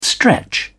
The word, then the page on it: stretch: British English